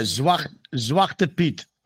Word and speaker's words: Zwarte Piet: Yeah, Zwarte Piet.